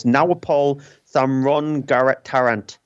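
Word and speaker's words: Nawapol Thamrongrattanarit: It's Nawapol Thamrongaratnarant. – I think that's exactly how he pronounces it, to be honest.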